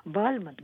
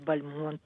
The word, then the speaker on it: Бальмонт: Со второго, кстати, легче всего начать, потому что Бальмонт сам в своих воспоминаниях писал, как так получилось, что он стал Бальмонтом. Считается, что после абсолютно точно только так стали его произносить после того, как он эмигрировал во Францию из Советской России. А до того он все-таки был Бальмонт, и сохранилось его родовое имение под Иваново.